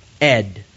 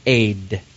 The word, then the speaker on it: ayd: The Hebrew word for witness there is transliterated into English, A-Y-D. Some people pronounce that Ed, but I just cannot have an altar named Ed. I just can't have that. So I pronounce it Aid. A-Y-D. I think that's actually the way it's pronounced.